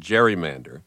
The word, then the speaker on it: gerrymander: That was the origin of the word gerrymander, which somehow acquired a soft G over the course of the following century.